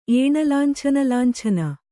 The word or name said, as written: ಏಣಲಾಂಛನಲಾಂಛನ